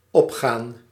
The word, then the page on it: opgaan: /ˈɔpxaːn/